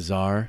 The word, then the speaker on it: Czar: And I see is silent. I think. – Yes. The Czar.